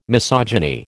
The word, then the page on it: misogyny: American English